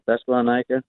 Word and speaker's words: Thessalonica: Thessalonica? – That's correct.